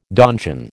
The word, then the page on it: donjon: American English